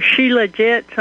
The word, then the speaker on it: Shilajit: Sam, have you heard of this supplement called Shilajit? Or Shilajit, some people pronounce it that way.